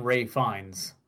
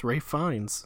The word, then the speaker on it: Ralph Fiennes: No, you were right. It's Ray Fiennes. That's how it's pronounced. – Okay. – Yes, for some reason, it looks like Ralph Fiennes, but is pronounced Ray Fiennes.